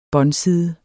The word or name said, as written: båndside